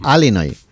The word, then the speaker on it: Illinois: Se pronuncia Illinois. – Sí, perdón, sí. – Illinois. – Pues yo digo Illinois, si no entendemos todos. Porque como diga Illinois, nadie lo entiende.